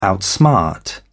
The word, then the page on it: outsmart: British English